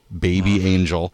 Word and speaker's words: BB/ANG3L: It's spelled BB/ANG3L, Baby Angel.